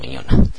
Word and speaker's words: Mignogna: Ya lo habíamos comentado al principio de este boletín de noticias, es Eduardo Miñona. Eduardo Miñona, que bueno, es Mignogna, ya sabéis cómo se escribe, yo nunca he sabido decirlo exactamente bien, pero bueno, podemos decirle Eduardo Miñona, ¿no?